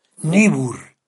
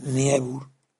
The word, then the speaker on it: Niebuhr: Niebuhr. Se escribe Niebuhr. – Bueno, es que como es alemán la palabra, Niebuhr, Niebuhr.